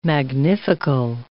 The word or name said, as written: magnifical